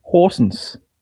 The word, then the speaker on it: Horsens: If you were to pronounce it right, there would be a place called Aarhus, where I live, and a bit further south there's a place called Horsens, where some people live.